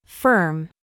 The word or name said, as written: FIFE